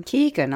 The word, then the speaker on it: Keoghan: Doctor Who, Barry Keoghan, Saltburn Banshees of Inisherin. Do you say Keoghan or Keoghan? – Keoghan, I would say.